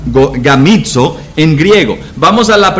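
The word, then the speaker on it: gamizo (γαμίζω): La palabra griega es, se pronuncia gamizo. Gamizo.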